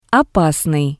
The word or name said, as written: opasnii